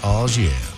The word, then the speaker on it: au jus: Do you know that au jus sauce is actually pronounced au jus? – You are a father.